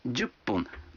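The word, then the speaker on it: джуппун (十分): А 10 минут – джуппун. Вы заметили, что когда фун добавляется к 10, дю, то произносится пун? – Послушайте и повторите каждую фразу.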